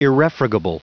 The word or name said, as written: irrefragable